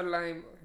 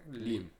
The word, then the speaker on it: Liam: Liam, Liam oder Lime? Liam.